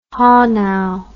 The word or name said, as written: Hanau